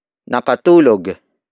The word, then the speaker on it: nakatulog: Word for word, it's nakatulog siya dahil dyan. Nakatulog siya dahil dyan. Nakatulog siya dahil dyan. The first word is nakatulog. Nakatulog. Nakatulog. Nakatulog is spelled as N-A-K-A-T-U-L-O-G.